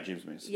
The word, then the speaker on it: James Mason: Did I say it right? – Yeah, James Mason.